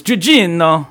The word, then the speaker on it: Gugino: She is Italian. – So it's Gugino.